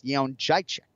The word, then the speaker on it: Jędrzejczyk: It seems like the adopted pronunciation is Jędrzejczyk, but in actuality, it's Jędrzejczyk. – Yeah, I think you're right. And I mean, I guess we can give it. If we're being generous, we can give him that pronunciation. – Generous. – But I speak English. That's how you say it in English. All right. – Yeah, but it's how the fighter says it, right? That's what we're going with.